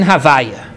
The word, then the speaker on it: Havaya: The ineffable name, the name of four letters, is pronounced in conversation Havaya.